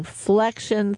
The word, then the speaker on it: Flexion: Now, I'm saying Flexion Therapeutics. It's how I introduced you, and it's spelled F L E X I O N, flexion.